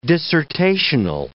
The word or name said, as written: dissertational